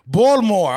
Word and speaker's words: Baltimore: Baltimore, right? Baltimore, all right.